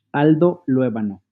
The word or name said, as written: Aldo Raúl Luévano Ibarra